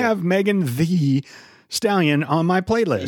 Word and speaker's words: Thee: Obviously, we do not wish that upon Megan Thee Stallion, T-H-E-E. – Yeah. – Thee. – You said it wrong earlier. So yeah, I'm sorry. – Said Megan Stallion.